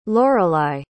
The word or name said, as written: lorelei